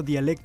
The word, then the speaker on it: Dialek: Me acabo de enterar que se pronuncia Dialek todo el tiempo. Dije Dalek, debí haberme visto como un torpe.